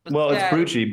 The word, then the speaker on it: Brutsche: It's Brutsche, but probably Brutsche.